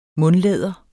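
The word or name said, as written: mundlæder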